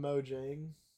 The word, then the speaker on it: Mojang: But I just call it Mojang. Just because that's how it's always been pronounced.